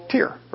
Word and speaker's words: Tyre: Well, Hiram was a Gentile king of a place called Tyre, or Tyre, some pronounce it Tyre.